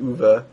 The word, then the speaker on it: Uwe: It's spelled U-W-E, but it's pronounced Uwe.